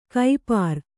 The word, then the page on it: ಕೈಪಾರ್: Kannada